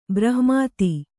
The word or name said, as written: ಬ್ರಹ್ಮೇತಿ